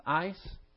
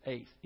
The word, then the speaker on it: eis: I know that a lot of you have seen this word EIS pronounced ice because there's a certain church where they pronounce it that way, but it's the correct pronunciation is ace, EIS.